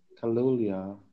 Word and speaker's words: Kaluuya: Kaluuya, yeah.